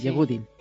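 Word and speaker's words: Yehudi: Grandes profesores la han curtido en este campo, ha dado clases magistrales con el Yehudim Menuhin, lo he dicho bien, Yehudim.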